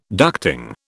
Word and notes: ducting: British English

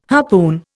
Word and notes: harpoon: Indian

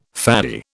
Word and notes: faddy: British English

